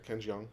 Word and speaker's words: Ken Jeong: How do you pronounce his name? – I think that's right. Ken Jeong.